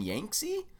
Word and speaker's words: Yangtze: We started yesterday with an appointment at Direwolf where we got to take a look at the digital adaptation of Yellow and Yangtze.